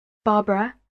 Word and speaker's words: Barbara: My name is Barbara Cooper. I'll spell it for you. Barbara, B-A-R-B-A-R-A, Cooper, C-O-O-P-E-R.